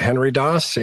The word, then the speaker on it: Henry Daas: So uh, the simplest place is to go to my personal website, Henry Daas, H E N R Y D A A S.